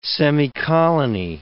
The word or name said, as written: semicolony